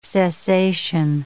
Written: cessation

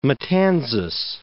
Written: Fort Matanzas National Monument

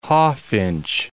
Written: hawfinch

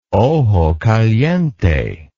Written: ojo caliente

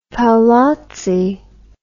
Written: paolozzi

